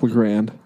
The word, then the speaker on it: LaGrand: Eric LaGrange was his name? – Yeah, I remember. – Eric LaGrand. – LaGrand? – That is the correct pronunciation.